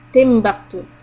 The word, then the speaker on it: Timbuktu: There's a country called Timbuktu, but it is written as Tin, T-I-N, but it is pronounced Timbuktu.